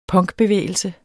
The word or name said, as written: PUNK